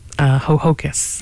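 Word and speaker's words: Ho-Ho-Kus: I said that right. Hohokus. – Yes.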